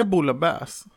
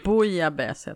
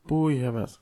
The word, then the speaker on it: bouillabaisse: Heter det boulebäs? Nej. Nej. Bouillabaisse heter det. – Bouillabaisse.